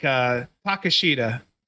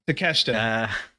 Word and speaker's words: Takeshita: And like Takashita, Takeshita.